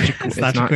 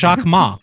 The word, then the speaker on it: Jacquemus: It's not Jaquemus. You're telling me it's not Jaquemus? – No, it's Jacquemus, right? Is that wrong? I got it wrong too? – Yeah. – What is it? Jacquemus, like Jacquemus, like Jack and Moose.